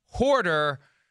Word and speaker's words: hoarder: Chris Sims uses a term that has one fewer syllable than that, and I have tried to convince him that the correct term is hoarder, not that word without the der.